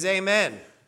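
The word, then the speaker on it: Amen (אמן): That's how you say it in Hebrew, is Amen.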